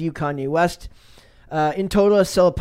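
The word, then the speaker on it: Selah: So it says Sela, is it Sela? I'd imagine Sela. It's pronounced Sela Marley.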